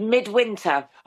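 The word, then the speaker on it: Mydwynter: It's called Midwinter. – Okay. – And like our name, like the name Mediaeval Baebes, it's not spelt in a modern way. It's spelt with a Y, M-Y-D, W-Y-N-T-E-R, which is a medieval spelling.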